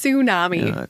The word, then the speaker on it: tsunami: It's tsunami, tsunami. You don't pronounce the T.